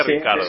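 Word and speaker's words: Ricciardo: Richard, Ricardo. Ricardo. Bueno, ojo, ojo, él dice que se eh, pronuncia Ricardo.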